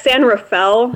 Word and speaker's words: San Rafael: It's San Rafael. – Rafael. – It's a very anglicized pronunciation now, but yeah, it is in San Rafael.